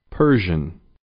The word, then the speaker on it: Persian: Persian. Per. Persian. Persian. – توجه داشته باشید که کلمه Persian را به صورت پر بیان نکنید. این صدایی است بین او و ا. به تلفظ آخر هم دقت کنید.